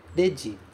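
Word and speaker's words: Dedi (レジ): Dedi, novamente, começa com R-E, mas é pronunciado de.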